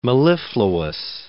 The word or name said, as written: mellifluous